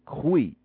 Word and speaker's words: goeie: G-O-E-I-E, but it's pronounced Kwe, like Q. So Kwe Anand is good evening. N-A-A-N-D, Kwe Anand means good evening.